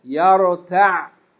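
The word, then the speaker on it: يرتع: But we're going to stop on the ayn without pronouncing it fully because it has sukoon. So we say يَرْتَعْ.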